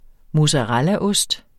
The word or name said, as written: mozzarellaost